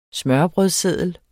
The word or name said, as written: smørrebrødsseddel